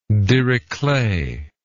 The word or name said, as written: dirichlet